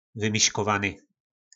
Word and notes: vymiskovany: po našimu

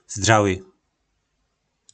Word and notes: Zdraly: po našimu

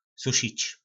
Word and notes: susic: po nasimu

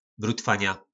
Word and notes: brutvana: po nasimu

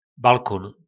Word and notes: balkon: po nasimu

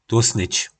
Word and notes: Tlosnyc: po nasimu

